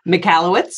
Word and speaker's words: Michalowicz: It's written by Michael Michalowicz, if I'm pronouncing that correctly. Is that correct? – Michalowicz.